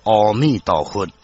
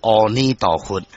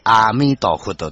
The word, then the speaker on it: 阿弥陀佛: 所以讲，咱即马一直伫咧念阿弥陀佛，阿弥陀佛，念一个句啊，煞变成阿弥陀佛。阿弥陀佛，因为咱台湾人是台湾腔，所以有人念阿弥陀佛。那正确的发音是阿弥陀佛，或者是阿弥陀佛，绝对不是阿弥陀佛就对。